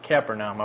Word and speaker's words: Capernaum: Verse 21, and they went into, and I always say this, Capernaum. I wish they'd spell it a little different.